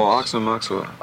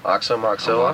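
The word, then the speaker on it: Aoxomoxoa: Oh, Oxomoxoa. – Oxomoxoa.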